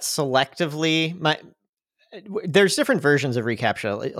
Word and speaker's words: reCAPTCHA: And so implemented, is it called reCAPTCHA? Is that how you say it? – Mm-hmm. Yeah.